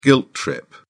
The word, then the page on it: guilt trip: British English